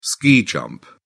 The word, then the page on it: ski jump: British English